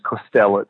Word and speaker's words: Kostelec: No, you got it right in terms of how I pronounce it. The native Slovene is Kostelec, so I don't expect anybody to do that.